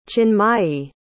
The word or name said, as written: chinmayi